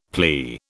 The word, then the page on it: plea: British English